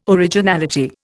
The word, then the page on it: originality: Indian